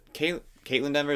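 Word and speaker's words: Kaitlyn Dever: But it's really kind of just the mom, because at least in the movie, from what I remember, the Caitlin Dever, did I say that right? – Yeah.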